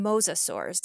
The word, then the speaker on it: mosasaurs: Your research so far in your career has primarily focused on mosasaurs. Did I pronounce that correctly? – That is correct.